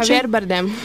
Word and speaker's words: Javier Bardem: però questo Xavier, com'è che si pronuncia? – Javier Bardem.